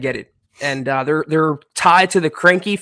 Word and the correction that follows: Kroenke: family, Kroenke, the in Kroenke.